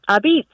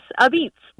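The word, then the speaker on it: apizza: Abeats. – Abeats. – Like it would be A-B-E-E-T-S, Abeats. – With a stress on the last syllable, right? Abeats.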